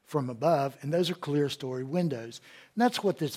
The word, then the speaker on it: clerestory: And it's, some people have pronounced it clerestory, but it's clear story.